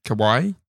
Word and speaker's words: Kawhi: His name is Kahai Leonard. – Kahai Leonard. – Yeah, or K-A-W-H-I. How do you want to pronounce that? – K-A-W-H-I. – Mm-hmm. – Kawaii? – Kawaii. Kawaii Leonard.